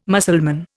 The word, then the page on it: Mussulman: Indian